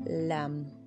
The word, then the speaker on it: Lam: Mantra que lo activa, la palabra LAM l m Lam. Se pronuncia prolongado.